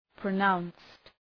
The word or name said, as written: pronounced